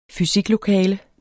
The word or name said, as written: fysiklokale